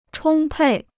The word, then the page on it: 充沛: chōng pèi